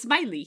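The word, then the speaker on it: Smyly: It's not spelled like the John Le Carré character, but it is. It's Smiley. He's Smiley.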